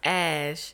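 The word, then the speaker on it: Æ: AE is pronounced Ash.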